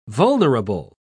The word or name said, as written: vulnerable